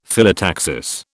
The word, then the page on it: Phyllotaxis: British English